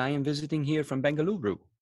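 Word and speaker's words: Bengaluru: Bangaluru. – Huh? – Bangaluru is Bangalore, just how they say it locally.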